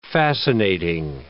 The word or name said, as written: fascinating